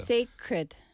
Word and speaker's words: sacred: Sacred. – Sacred. Sacred night. – Secret처럼 근데 sacred. C-R-E-D. Cred. 그거는 어렵다. 한국 사람 뭐 발음 때문에 우리 받침 때문에 받침 필요하니까 그래서 sacred, sacred.